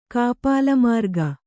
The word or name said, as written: ಕಾಪಾಲಮಾರ್ಗ